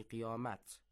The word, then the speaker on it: قیامت: این کلمه در فارسی هم استفاده می شود و البته ته آخر آن تلفظ می شود، یعنی قیامت.